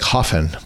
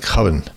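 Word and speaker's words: höfn: Starts with L-A-N-D-E-Y-G-A, and then it ends with H-O-F-N, which is looks like Hoffen, but it's pronounced more like Hoven.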